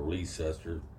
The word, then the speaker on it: Leicester: There, outside a little town called Leicester or Leicester. Up there, it rhymes with Chester.